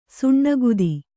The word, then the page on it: ಸಿನಿಕತೆ: sinikate